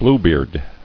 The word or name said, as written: Bluebeard